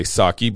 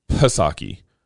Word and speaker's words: Psaki: I know people like to say Psaki, but I think that's wrong. It's Psaki. – You pronounce the P.